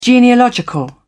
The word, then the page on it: genealogical: British English